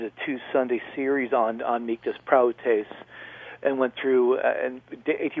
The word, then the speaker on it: prautes: But yeah, praotes, P-R-A-U-T-E-S, long E, the second one, that's exactly what it is, and it was used.